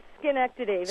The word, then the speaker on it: Schenectady: How do you pronounce that city? – It's Schenectady. Schenectady.